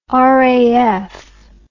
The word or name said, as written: raf